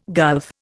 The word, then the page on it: gulf: Indian